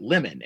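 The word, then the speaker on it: Limon: it would pronounce it lemon. And I'm like, well, that's kind of believable that it would be, you know, spelled that way, but it's pronounced lemon.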